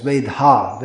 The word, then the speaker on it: Vedha: Veda. Oh, it's Vedha. Vedha, sorry. It's not Veda. Veda is Shastra, but this is in English that would be spelled V-E-D-H long A. So Vedha.